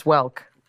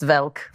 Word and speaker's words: Welk: My grandparents watched Lawrence Welk. – Yeah, of course. – Which they pronounced Velk.